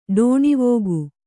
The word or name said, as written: ಠಾಣವಿಕ್ಕು